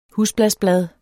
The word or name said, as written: husblasblad